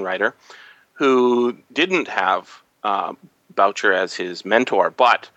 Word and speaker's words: Boucher: Anthony Boucher, or sorry, Boucher is how it's pronounced, was the editor of, uh, get, not Galaxy, um, science fiction and fantasy magazine, magazine of science fiction mm-hmm.